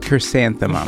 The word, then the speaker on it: Chrysanthemum: I made a carminthamum. – Carminthamum. – Is that how you say it? – Chrysanthemum? – Chrysanthemum. – Like the flower? – Yes, I can't say that word.